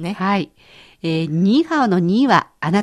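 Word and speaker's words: 你好: ニーハオ。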